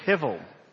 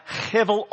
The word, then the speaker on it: hevel: The H is guttural, and the B is pronounced as a V. So, one, two, three, hevel. And he pronounces in chapter 1, verse 2, that everything is a hevel of hevels.